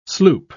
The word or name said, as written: sloop